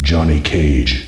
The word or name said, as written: jon cage